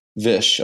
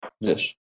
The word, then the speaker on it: Vish: Is it Vish or Vish, the pronunciation? – It's Vish. – Vish. Okay. I just wanted to make sure. Vish.